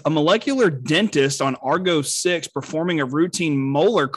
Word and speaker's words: Argo 6: Is it pronounced Argo 6? – Yeah.